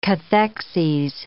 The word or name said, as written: cathexes